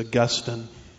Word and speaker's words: Augustine: And so you'll see, once you study Augustine, or I guess the right pronunciation of it is Augustine. It just depends which syllable gets the emphasis, as is said. So I think it's pronounced Augustine.